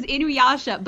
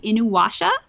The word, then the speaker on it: Inuyasha: And it was Inuyasha, but she could never say it properly, so it was always Inuwasha.